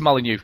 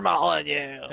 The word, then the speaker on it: Molyneux: Peter Molyneux. – Sorry. Oh. Yeah, no, I never knew that. Bullfrog Productions made it, but it was designed by Peter Molyneux.